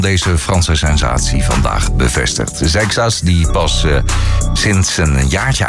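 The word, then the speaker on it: Seixas: Paul Sextas. Ja, je kan het uitspreken zoals je wil, maar zo moet je het doen. Sextas. De wielrenner, hij gaat zijn debuut maken.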